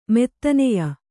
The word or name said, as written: ಮೋದನೀಯ